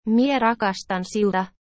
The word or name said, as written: mie rakastan siuta